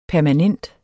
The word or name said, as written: permanent